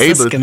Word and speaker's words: Ableton: Ableton, Ableton heißt Ableton, das, genau.